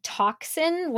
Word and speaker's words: tocsin: First, I have to say that she starts by talking about the word toxin, which sounds like the word T-O-X-I-N, but it's actually T-O-C-S-I-N, which means an alarm bell.